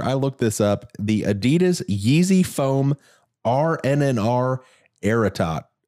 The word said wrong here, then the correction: Ararat: Ararat. How do you say it? A-R-A-R-A-T.